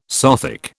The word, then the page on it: Sothic: British English